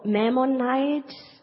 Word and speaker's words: Maimonides: it's called Maimonides.